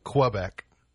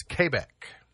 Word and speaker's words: Quebec: I don't know if you know this or not, but we're not supposed to pronounce it Quebec. It's Quebec. – Hmm. – That's how it's supposed to be pronounced.